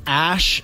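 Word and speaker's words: æ: and then they're trolling Twitter with this because someone figured out that the AE is a, it's called an E ligature. I don't know what that is, but it's pronounced Ash.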